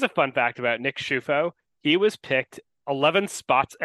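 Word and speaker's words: Ciuffo: All due respect to the Cioffo family. – Or what, however it's pronounced, but yeah. – Baseball reference tells me that it's Schufo, Nick Schufo, which I guess makes more sense.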